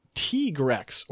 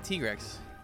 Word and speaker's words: Tigrex: That's just the way I've heard it often pronounced. But I think you could go either way. I don't know officially. I've just always gone Tigrex because I think that's what I've heard as far as like hearing Japanese players pronounce it. – I mean, that is how it would be pronounced from the Japanese, but you know.